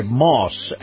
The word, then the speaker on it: Maus: All righty, and my conversation with Chris Markowski brought to you by Moss Acura, that's spelled M-A-U-S.